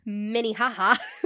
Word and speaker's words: Minnehaha: I can't even pronounce this county. Minnehaha. It's a Minnehaha.